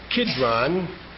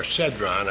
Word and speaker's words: Cedron: The brook Kidron, or Cidron, as the Bible sometimes calls it, or Cedron, I believe is the right pronunciation,